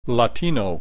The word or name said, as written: Latino